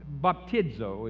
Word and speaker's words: baptizo: As I told you before, John baptized, baptizo is how you pronounce that, with.